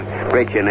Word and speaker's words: Brecheen: That coming off Spud Crist, and Crist was later relieved by Rasheen, or Rasheen, however he pronounces it. It's a little difficult for me because I've been calling him Rasheen all season, but I believe Rasheen is correct.